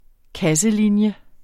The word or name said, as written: kasselinje